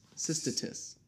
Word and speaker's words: cystitis: Diluted versions later got approved for treating interstitial cystitis. I don't know. That's a hard freaking word. Try and pronounce this. C-Y-S-T-I-T-I-S. Cystitis. – C-Y-S-T-Y. – No, C-Y-S-T-I-T-I-S.